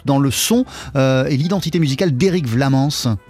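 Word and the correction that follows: Erik Vloeimans: eh ben, et, et, d'ailleurs, je, suis bien conscient que j'écorche son nom depuis le début de l'émission, donc si tu veux rétablir la bonne prononciation, vas-y. – C'est Vloimans. Merci.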